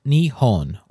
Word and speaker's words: Nihon: Thus, we have a kanji compound meaning the sun's origin, which in Japanese is pronounced Nihon or Nippon.